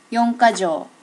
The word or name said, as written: yonkajo